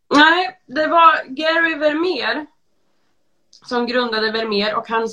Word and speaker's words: Vermeer: Ja, det är många som tror det och det är många som tror att vet uttalas med F. Men det ska ju vara Vermeer med V. – Vermeer.